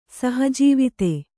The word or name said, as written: ಸಹಜೀವಿತೆ